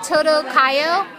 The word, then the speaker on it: Totokaelo: And there is a shop out in Seattle called Todo Cayo. I don't know if anyone out there has heard of it. It's a Native American word, so it's not spelled like it sounds.